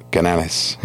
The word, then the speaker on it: Canales: Would you tell me if I wasn't? – It's close. – Okay. – I mean, it's Canales. – Canales. – Yeah.